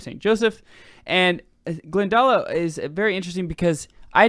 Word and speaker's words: Glendalough: And from Kildare, we're going to go to, on day 8, Glendalough. How do you say that? – Yeah. – Glendalough? – Yeah.